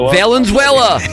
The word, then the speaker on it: Valenzuela: Valenzuela.